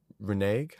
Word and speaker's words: renege: A big issue, I think, is when they set that date to then renege. How do you pronounce that word? – Renege. – To then renege. – To renege.